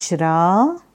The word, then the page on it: Vajraa: Sanskrit